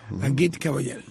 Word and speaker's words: Agit Kabayel: Creo que es Agit Kabayel. – Sí. – Agit Kabayel. – Muy bien.